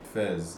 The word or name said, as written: Fez